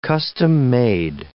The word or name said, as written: custom-made